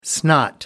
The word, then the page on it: snot: American English